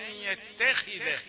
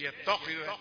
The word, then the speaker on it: يتخذ: أن يتخذ يتخي، اسمع سجل في دماغك أن يتخذ مش يتخذ. النطق الخطأ خطأ من ناحيتين، فخم التاء وليست هي من حروف التفخيم أبدا، وأعطى الخاء درجة أعلى من حقها في التفخيم، هي مكسورة فتفخم في الدرجة الخامسة.